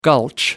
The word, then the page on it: gulch: American English